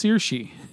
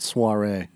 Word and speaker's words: Saoirse: So her name is pronounced Saoirse. – Yes. Not Soiree.